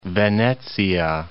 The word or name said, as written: Venezia